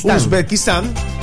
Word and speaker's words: Uzbekistán: Es decir, el próximo 17, cuando juegue Colombia frente a Uzbekistán. – ¿Sí está bien pronunciado? – Uzbekistán.